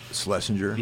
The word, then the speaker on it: Shlesinger: Like I saw uh, I don't know how to pronounce her name, Iliza Shlesinger.